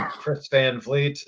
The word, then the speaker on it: Chris Van Vliet: And that is how you pronounce it, by the way. I hear some other podcasts will pick up clips from my interviews and call me Chris Van Vliet or Chris Van Violet or whatever you want to call me. Yeah. Chris. Oh, yeah. He's from Canada. It must be French. Chris Van Vliet. So thank you guys for pronouncing it correctly, and thanks for hanging out.